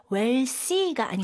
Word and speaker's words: wealthy: 이거는 발음 조심하셔야 돼요. TH 발음 살짝 메롱하셔야 돼요. wealthy가 아니고 wealthy.